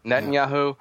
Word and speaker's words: Netanyahu: Netanyahu.